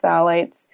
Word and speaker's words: phthalates: It's phthalates. And that starts with a P, but it's pronounced phthalates.